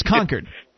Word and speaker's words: Concord: People oftentimes think that there's a place called Concord, New Hampshire. There is no such place. – It's Concord. – Concord. – Right.